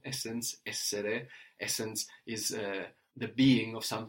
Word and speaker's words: essere: To be in Italian, so the to form to be is essere, essere. – Essere. – That's spelled E-S-S-E-R-E, essere. – Essere. – So we have the accent there free back from the end, or at the beginning, no? In this case, essere. Like in vendere, to sell, or conoscere, to know.